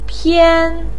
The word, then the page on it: pian1: pinyin